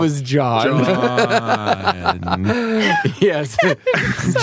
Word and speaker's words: Jaun: It's actually J-A-U-N. Like John.